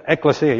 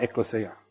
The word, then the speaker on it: ecclesia: Yeah, ecclesia, yep. Tell your mum I said it right, ecclesia.